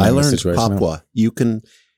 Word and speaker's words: Papua: Papa. – No, it's Papua. – I think, isn't it not pronounced Papa? – It, no.